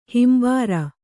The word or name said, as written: ಹಿಂಬಾರ